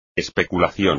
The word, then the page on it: especulación: espagnol